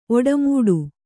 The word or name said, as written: ಓಡುಮಾಡು